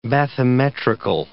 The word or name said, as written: bathymetrical